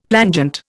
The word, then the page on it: plangent: Indian